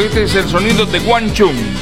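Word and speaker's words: Wang Chung: Así como lo escuchaste, Guanchum. Dance Halls Days. Esa es la pronunciación correcta.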